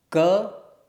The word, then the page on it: k: sanskrit